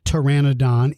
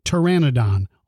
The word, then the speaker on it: Pteranodon: It starts with the letter P, but you do not pronounce that letter in this particular name. So it's not Pteranodon, it's Pteranodon, almost as if it only starts with the letter T.